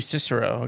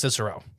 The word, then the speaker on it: Cicero: I was at Rosen's, which is Alpha Banking, at Cicero Avenue. – Ooh, Cicero, okay.